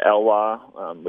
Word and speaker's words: Elwha: Before we close, I do want to have you tell me about your trip down the Elwha River. Is that how you pronounce it? – Yeah. – In Washington? – Elwha, up on the Olympic Peninsula of Washington.